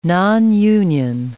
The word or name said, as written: nonunion